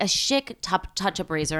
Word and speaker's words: Schick: How do you say that? – Schick.